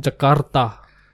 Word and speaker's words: Jakarta: I just got back from Jakarta. – Ooh. – Notice right. The rolling of the R. – Yes, yeah. That's how they say it. – Do they? – Jakarta.